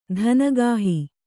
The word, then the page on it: ಧನಗಾಹಿ: dhanagāhi